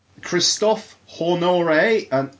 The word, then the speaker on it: Honoré: Christophe Honoré. – No. Would laugh my ass off because his surname is basically pronounced Horny.